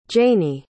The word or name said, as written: jaynie